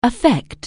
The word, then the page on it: affect: anglais